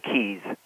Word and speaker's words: Keis: I know it's spelled Keis, but we actually like to pronounce it Keys.